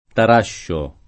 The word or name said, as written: Tarascio